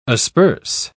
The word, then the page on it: asperse: British English